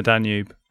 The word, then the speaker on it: Danube: Friend of the podcast and a man who has his own jingle, Kieran Alger, is currently running the Danube. And But we should do a jingle for him because Danube rhymes with YouTube, and that was enough for me.